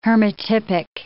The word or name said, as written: hermatypic